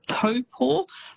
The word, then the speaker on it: Taupo: but famous spots including Taupo. So quite often you'll hear that said as Taupo. – Yes. – It's almost how it's spelt, but that Taupo is actually pronounced Taupo.